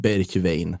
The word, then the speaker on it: Bergwijn: Han hade skrivit att så här uttalas det, alla kommentarer. Och då läste jag i alla fall det han skrev som Bergwijn.